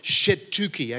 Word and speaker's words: Shetuki: It was called Sheketui. Let me get the pronunciation right. Shetuki, actually. Shetuki.